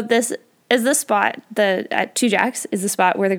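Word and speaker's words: Tujague's: And interestingly, Two Jacks is spelled T-U-J-A-G-U-E, apostrophe S. So not exactly how you would think of Two Jacks, but that's how you say it, apparently.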